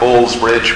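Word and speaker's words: Baal's Bridge: Linking the two parts of the town, we have Ball's Ridge, which is spelled with a double A, like Ball.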